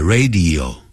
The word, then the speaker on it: radio: On the radio, 那个 radio 不要念成 radio, 更不可能念成 radio.